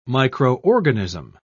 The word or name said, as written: microorganism